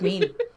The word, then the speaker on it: mien: Fearsome Maine? Is it M-E-I-N? – M-I-E-N. – M-I-E-N. – But you know what? I haven't pronounced that word in like a decade, so I think it's mean.